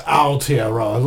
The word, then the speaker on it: Aotearoa: That's pronounced Aotearoa, like not your Tearoa, it's Aotearoa, bro.